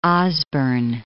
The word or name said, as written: Osborne